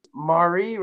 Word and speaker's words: Mari: Mari, right? I said that right? – Yes. Mari rhymes with Atari, Ferrari, and Safari.